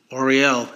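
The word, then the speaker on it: L'Oréal: I always have a problem pronouncing that damn Elroy. L apostrophe O R E A L. Oriel. Yeah, there you go.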